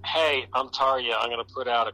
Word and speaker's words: Tarja: Is that how it's pronounced, Tarja? – That's how it's pronounced, yes.